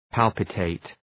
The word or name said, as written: palpitate